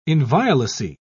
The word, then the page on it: inviolacy: British English